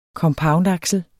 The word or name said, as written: compoundaksel